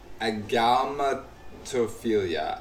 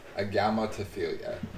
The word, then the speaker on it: agalmatophilia: We've got agalmatophilia. Agalmatophilia. Agalmatophilia. Agalmatophilia. – Agalmatophilia? – Yep. – Spell it one more time, the beginning. – Agalma. Agalma. – Uh-huh. – And then it's Tophilia. Tophilia. Agalmatophilia.